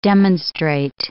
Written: demonstrate

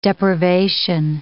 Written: depravation

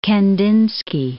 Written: Kandinsky

